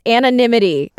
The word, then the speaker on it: anonymity: And I still said that wrong. It's anonymity, according to everybody.